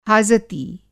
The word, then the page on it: हाजती: Hindi